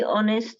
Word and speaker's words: honest: To be honest with you, I'm quite afraid that robots will be dangerous in the future. It's a truthful, honest. – Honest. Remember, H is silent, mm-hmm. Honest.